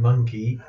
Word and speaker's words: Mynci: And then monkey is Mynci. Monkey.